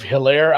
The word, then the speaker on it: Hilaire: I was a little worried about that because I made it based on numbers, and then also, now you have Hilaire out with a silent H.